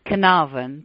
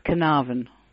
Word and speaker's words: Carnarvon: Well, in England, it's Carnarvon, but in America, it may be slightly different. But in England, it's Carnarvon.